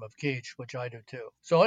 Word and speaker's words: Kizh: Uh, their true name is uh, Kizh, spelled by scholars like me K I Z H, but pronounced like teach or reach.